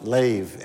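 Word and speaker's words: lev (לב): It's either L-E-B or L-E-B-A-B. They pronounce it lev in the Hebrew.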